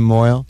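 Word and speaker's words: mohel: I don't like the name Moyle. It rhymes with boil.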